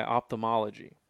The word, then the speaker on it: ophthalmology: But nonetheless, they were fascinated by eye surgery and by ophthalmology.